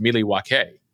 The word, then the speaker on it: Milwaukee: And Alice Cooper goes, actually, it's pronounced Milwaukee, which is Algonquin for the good land.